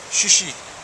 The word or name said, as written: shishi